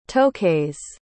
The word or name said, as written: toakase